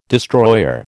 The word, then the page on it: destroyer: American English